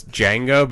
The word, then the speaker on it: Django: And like, you said it wrong because it's Django, but you said just like the... Django Unchained. I'm like, right. Oh my god. – Well, I forgot to finish saying. Oh, excuse me. What? What I forgot to say after that was the D is silent.